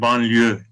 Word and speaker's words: banliyö: Evet, şimdi bu e, bu kelime çok ilginç bir kelime banliyö kelimesi. Türkçe'de e, telaffuzu aynen Fransızca'da olduğu gibi yani Fransızca'da bu kelime nasıl telaffuz ediliyorsa Türkiye'de de aynı şekilde e, telaffuz ediliyor.